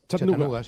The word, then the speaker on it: Chattanooga: Chattanooga, sí.